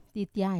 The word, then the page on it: titiai: Wamesa